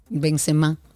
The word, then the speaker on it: Benzema: Acento en la la última serie. ¿Y por qué no tiene acento entonces? – Benzema. – Bueno, porque así que se pronuncia, no lleva acento.